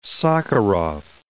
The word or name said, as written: Sakharov